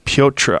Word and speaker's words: Petr: Piotr. – I think you're supposed to say Piotr.